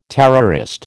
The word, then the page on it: terrorist: American English